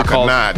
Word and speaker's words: Akhenaten: Uh, Napoleon and I, I think it's Akenaton. God, I could have butchered that name. They have a they have a project together Akhenaten. called Akhenaten. There we go.